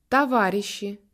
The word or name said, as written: camarades